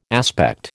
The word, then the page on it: aspect: American English